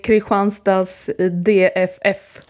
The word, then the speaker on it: Kristianstads DFF: Yeah, in Sweden, we say Kristianstads DFF.